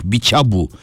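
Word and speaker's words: Bitshiabu: ben ş ile Bichabu diye yapmıştım. Ç okunuyormuş, Bichabu okunuyormuş.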